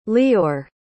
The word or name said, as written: leeor